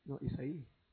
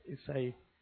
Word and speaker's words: Isaí: Y le Isaí. – Oh, Isaí. I don't know how to say it. – Isaí.